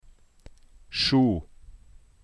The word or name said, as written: Schuh